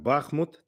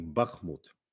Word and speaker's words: Бахмут: В словаре ударений можно как Бахмут, так и Бахмут.